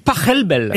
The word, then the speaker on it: Pachelbel: S'il est allemand, ça se dit Pachelbel.